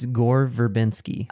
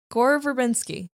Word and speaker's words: Gore Verbinski: Is it, say, how do you pronounce his first name? – It's Gore Verbinski. – Oh, it is Gore.